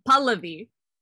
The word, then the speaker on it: Pahlavi: It's Pahlavi. – Pahlavi. – Yeah. So the emphasis is on the first syllable, and it's like an Like my friend, Fizz actually told me I should tell people that it's like Pali. It's like Pahlavi.